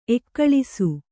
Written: ಏಗೊಳಿಸು

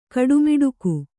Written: ಗುಡಮೇಟಕೆ

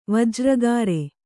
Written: ವಜ್ರಗಿರಿ

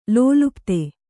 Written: ಲೋಲುಪ್ತಿ

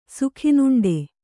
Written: ಸುಖಿನುಂಡೆ

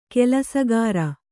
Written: ಕಲ್ಲುಸಕ್ಕರೆ

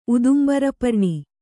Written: ಉದುಂಬರಪರ್ಣಿ

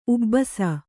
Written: ಉಬ್ಬಿಸು

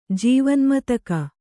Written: ಜೀವನ್ಮತಕ

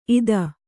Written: ಇತ್ತು

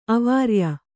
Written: ಅವಾರ್ಯ

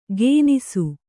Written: ಗೇನಿಸು

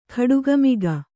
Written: ಕೋಡುಗಮೊಗ

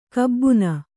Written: ಕಬ್ಬುನ

